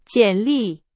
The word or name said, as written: 简历